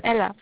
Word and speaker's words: Ella: It's Ella.